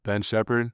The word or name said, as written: Ben Sheppard